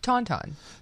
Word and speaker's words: Tauntaun: Tauntaun.